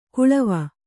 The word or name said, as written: ಕಳವೆ